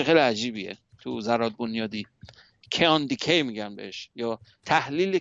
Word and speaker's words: kaon: بعدن یه چیز دیگه پیدا کردن تو دهه 70، کیان، ما تو فارسی فکر کنم میگن کاون یا کیان میگن، درستش تلفظش کیانه، K-A-O-N، کیان.